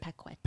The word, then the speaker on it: Paquette: Is that how you say it? – Paquette. – Yes.